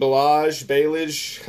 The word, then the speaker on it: Ballage: I started Kalen Ballage. How do you say that? Is it Ballage? – Ballage. Okay, that's what I thought.